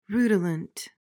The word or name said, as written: rutilant